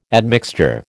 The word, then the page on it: admixture: American English